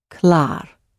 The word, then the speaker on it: klaar: The word for ready or finished is klar, klar, klar. It is clearly ready. Klar, K-L-A-A-R, klar.